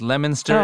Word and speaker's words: Leominster: Leominster is a town. – We've saved face, Helen. – Yes, it's spelled L-E-O-M, but it's pronounced like the fruit lemon.